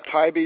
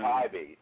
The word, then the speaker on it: Taibbi: Max Tabibi. – Matt Tybee, you mean? – Oh, right. – Matt Tybee.